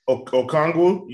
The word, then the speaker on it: Okongwu: Is that how you say his name? Inoki Ngonwa? I know I totally butchered his name, and I'm sorry, but... – Okongwu? You talking about Okongwu? – Yeah, there you go.